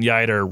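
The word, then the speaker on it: Yeutter: And it's Clayton Yeider rhymes with fighter.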